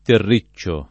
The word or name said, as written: terriccio